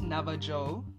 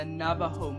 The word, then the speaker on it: Navajo: Let me just say it how it's spelled. Navajo. N-A-V-A-J-O. But assuming that it's Spanish, the J is... sounded like an H, so I'm gonna say Navajo.